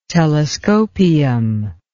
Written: telescopium

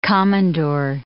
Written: komondor

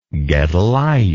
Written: gedaliah

